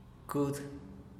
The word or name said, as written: cud